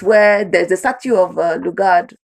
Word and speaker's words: Lugard: So Lugard is the proper pronunciation as opposed to Lugard, which we Nigerians tend to.